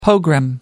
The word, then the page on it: pogrom: American English